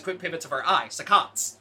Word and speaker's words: saccades: S-A-C-C-A-D-E-S. – Saccades. Saccades. – One would think it's called succsods, because that's like how usually two C's are said in science, but I looked it up and it's saccades.